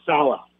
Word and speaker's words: Saleh: It's like Hala, Saleh.